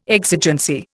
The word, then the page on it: exigency: Indian